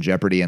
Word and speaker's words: Jeopardy: Clued as letter you don't pronounce in Jeopardy and Leopard, uh-huh. And is the O.